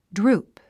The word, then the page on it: droop: drúːp; ドゥループ